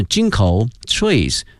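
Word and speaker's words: ginkgo: 它的发音呢是 Jinko trees， Jinko trees、同学们记一下银杏树哈， Jinko trees。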